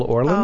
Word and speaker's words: Orland: Maxine Lapidus is live at Sterling's upstairs at Vitello's in Studio City with musical direction by American Idol's Michael Orland.